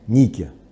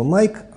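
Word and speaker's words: Nike: Его назвали Ники. Или англоязычные наши коллеги произносят это Найк, а корни – это греческая богиня Победы Ника.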